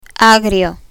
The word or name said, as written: agrio